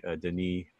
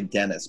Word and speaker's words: Denis: Not directed by the original director, instead by Denis Villeneuve. – Yeah, I believe that's how it's pronounced. It's Denis. I always say Dennis, but it could be Denis.